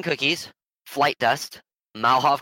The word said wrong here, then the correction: Molotov: cocktail. I'm not pronouncing that right. I know I'm not pronouncing that Molotov. – Right. – Molotov cocktail. – Molotov cocktail. Thank you.